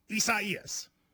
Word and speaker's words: Isaias: A lot of people, you know, prayers to everybody involved, but it is Isaias. – Is that how you say it, though? – You do. And it's hard because it's a fun name. And so you want to be like Isaias, but you can't do that with a hurricane.